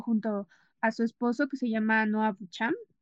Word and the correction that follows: Baumbach: ah, si lo pronuncio bien, ¿verdad? Ah, Baumbach,